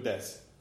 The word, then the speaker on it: desu: Right, des, and it's D-E-S-U, but you don't pronounce the U.